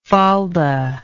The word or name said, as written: falda